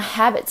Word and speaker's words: habit: 那我们在这边可以注意到 habit、 habitual 跟 habitually 这三个字呢，我们在发音的时候，我们的重音呢是放在不同音节的。那 habit 这个字呢，我们的重音是放在一开始的 ha 这个部分，所以我们在说 habit 的时候，我们可以听得出来它的重音是放在一开始的部分。